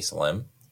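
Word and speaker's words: Salemme: But we don't pronounce the S in Salem, so it's Danny L-M. – It is Danny L-M.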